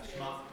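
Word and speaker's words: Shema (שמע): What's the Shema? Or anybody, Shema. Anybody. Am I saying it right? – Yeah, Shema.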